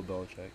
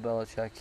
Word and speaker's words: Belichick: Bill Belichick. – Yeah, Bill Belichick.